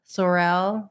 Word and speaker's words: Sorel: Sorrel? Am I saying it right? – S-O-R-E-L. Sorrel. – Okay, Sorrel boots.